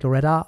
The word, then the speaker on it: Loretto: Ja, Loretta, es steht Loretto, aber es wird Loretta ausgesprochen.